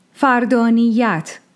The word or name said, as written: Fardāniyyat